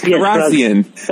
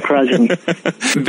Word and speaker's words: Kharrazian: Did I say that right? – Yes, Kharrazian. That's right, Kharrazian.